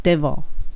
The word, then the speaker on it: devil: 歯の、えーと、歯を下の唇にタッチさせてその「 V って言った後にもう「レ」っていう風に L の発音をすれば出ます。 Devil です。